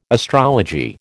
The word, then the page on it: astrology: American English